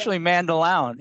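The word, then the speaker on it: Mandaloun: It is Mandaloon. That's how you're supposed to pronounce it. I looked it up multiple times. – But except it's the owner. It's the way the owner chooses to pronounce the name. So since every announcer is pronounced a Mandaloon, that is the pronunciation.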